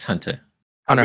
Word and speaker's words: Hunter x Hunter: How do you say Hunter X Hunter? – Hunter Hunter. Becky is supposed to be Hunter Hunter. – Shut up. – Oh, God. – How do you say the X? – The X is silent.